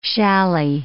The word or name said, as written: challis